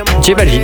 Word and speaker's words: J Balvin: Ça se prononce comment en espagnol du coup? Ray Balvin? – J Balvin.